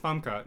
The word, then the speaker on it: Famke: No, it's pronounced Famke.